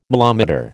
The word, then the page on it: milometer: American English